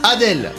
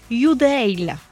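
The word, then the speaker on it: Adele: On dit Adèle, forcément, hein, oui. – on le dit comme ça est écrit, mais en vrai, la prononciation serait Youdale. – Youdale. – Oui, donc pas Adèle, Youdale.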